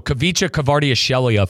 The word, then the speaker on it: Kvicha Kvaratskhelia: So Kvicha Kvaratskhelia, folks. – Great pronunciation, by the way.